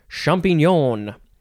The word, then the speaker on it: champignon: Next and last word for this episode is champignon. Champignon, champignon, champignon, champignon. You can say it that way too, champignon. You get it. It is spelled C-H-A-M-P-I-G-N-O-N, champignon.